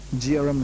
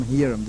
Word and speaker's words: Jearim: Jearim, Jearim, the J's are pronounced as Y's most often.